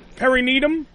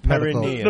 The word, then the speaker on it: perineum: By the way, perineum? – Yeah, is the technical term.